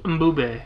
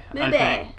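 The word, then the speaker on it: Mbube: It's M-B-U-B-E. – Mboobe. Mboobe. Because it's African. And I think that's how you pronounce stuff like that. When like the M and the B are right next to each other.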